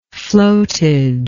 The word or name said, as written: floatage